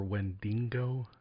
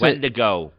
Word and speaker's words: Wendigo: Isn't it pronounced Wendigo or Wendingo? – Yeah, it's Wendigo.